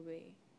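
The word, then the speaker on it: Wai: Y is particularly, it's either Y or Wei, it's W-A-I, that's how it's pronounced.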